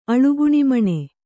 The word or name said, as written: ಅಳುಗುಣಿಮಣೆ